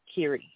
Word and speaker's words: Kiri: Kerry, okay. – Yeah. – Okay, perfect. And how did I spell that one? – K-I-R-I. – K-I-R-I, exactly how it sounds.